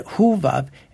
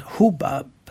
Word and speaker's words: Hobab: hette Hovab eller också uttalat Hobab.